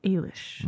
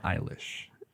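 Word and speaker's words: Eilis: Ailish. Not Eilish. – No, not Eilish. – It is spelled like Eilish, though. – It's like a pun on Irish and eyelash. but no, it's pronounced Ailish.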